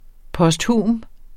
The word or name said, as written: posthum